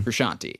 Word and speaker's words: Frusciante: Frusciante.